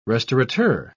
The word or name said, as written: restaurateur